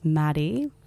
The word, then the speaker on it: Maddy: My name is Maddie, M-A-D-D-Y, Monacelli, which is spelled M-O-N-A-C-E-L-L-I.